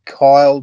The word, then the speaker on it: Kiel: My name's spelled K-I-E-L, so half the times it's Kyle and the other half it's Kiel.